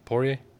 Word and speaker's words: Poirier: Poirier.